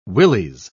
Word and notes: willies: British English

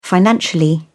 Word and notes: financially: British English

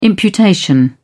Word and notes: imputation: British English

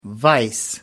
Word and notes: vise: American English

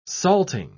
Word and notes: salting: British English

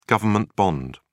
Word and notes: government bond: British English

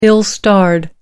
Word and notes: ill-starred: American English

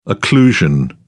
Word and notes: occlusion: British English

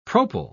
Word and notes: propyl: British English

